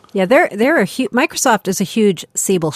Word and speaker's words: Siebel: But I saw a Siebel. Is that how it's pronounced in English? – Siebel. Siebel. Yep.